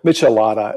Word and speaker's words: Michelada: One other thing that I came across when I was checking out your work before deciding to do a show, Michelada, how do you say it? – Michelada. It's a Michelada.